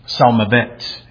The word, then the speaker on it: Salmavet: Salmavet is the way it's pronounced.